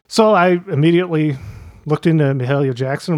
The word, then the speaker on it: Mahalia: So I immediately got the pronunciation correction there, because I think a lot of people are inclined to say Mahalia when they see her name, but it is Mahalia.